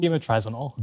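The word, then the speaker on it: bemotrizinol: Is it bimotrizole? – Bimotrizinol. – Bimotrizinol. Yes, exactly.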